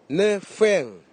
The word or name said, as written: nifeg